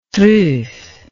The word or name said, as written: truffe